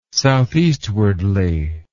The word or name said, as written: southeastwardly